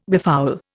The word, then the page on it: befoul: Indian